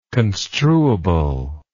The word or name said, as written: construability